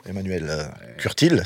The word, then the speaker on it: Emmanuel Curtil: Non mais les gens paniquent, hein, les comédiens, les doubleurs, notamment vous, Emmanuel Curtil, c'est comme ça que j'ai bien prononcé? – Oui, okay. Absolument.